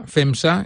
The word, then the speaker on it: FEMXA: FEMSA se escribe F-E-M-X-A, FEMSA, y por tanto la web es cursosfemsa.es.